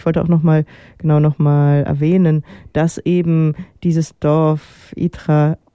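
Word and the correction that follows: Eythra: habe ich das jetzt richtig ausgesprochen? Egal. – Aitra, genau, ein Dorf bei Leipzig ist, was ja weggebaggert worden ist, wo es jetzt einen großen Tagesee gibt.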